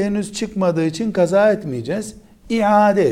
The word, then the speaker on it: iade: İade, ibadetin iade edilmesi. Kelimenin aslı iadedir. Ama biz Türkçe'de bunu iade diyoruz. İade. aynı harfinin telaffuzu zor olduğundan Türkçe için ibadeti iade etmek.